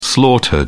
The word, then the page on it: slaughtered: British English